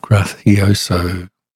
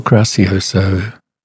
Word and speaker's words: Gracioso: Here we have Bernard Haitink with the Boston Symphony Orchestra giving us Alborada del Gracioso. Or Gracioso. No, that's Spanish, so it might be Alborada del Gracioso.